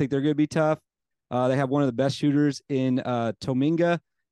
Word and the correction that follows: Tominaga: Am I saying that right? I've never said his name right. Is that how you say it? – I believe it's Keisei Tominaga or something Tominaga. – Similar. – Man, I said that way wrong then.